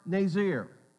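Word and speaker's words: Nazir (נצר): And that word branch means, it is pronounced Nazir.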